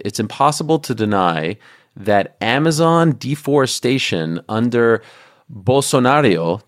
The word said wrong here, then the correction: Bolsonaro: did I say that correctly? – Bolsonaro, excuse me, I gave it a little Italian twist there, rose to historic highs and indigenous land rights dipped to historic lows.